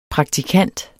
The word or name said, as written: praktikant